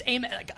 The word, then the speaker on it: Amen: So it's Amen. How do you pronounce the two Thompson's first names? Amen and Osser.